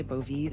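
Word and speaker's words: Bove: We already know he put Emil Bovee, Bovee, that's how you say his name, on the Third Circuit Court of Appeals.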